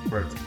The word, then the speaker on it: Bert: Because my mom couldn't pronounce the T, so she just says Bert. So when she says my name, she goes but. but she can say Bert.